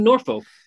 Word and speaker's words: Norfolk: And people in Nebraska pronounce it Norfolk because they say it's at the North Fork of the Platte River, which to me, I don't know, put a second R in there if that's how you want people to pronounce it then.